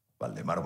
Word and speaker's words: Waldemaro: Entonces siempre me decía, epa, o no, WM, Waldemaro Martínez. Entonces me ahorraba el hecho de explicarles que con la W era una, es una W de origen holandés, que se pronuncia como B, y entonces, bueno, eh, quedó esa, esa marca.